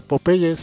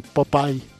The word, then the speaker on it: Popeye: Y de hecho hay que mencionar también que originalmente en inglés no se pronuncia Popeye, sino Popeye.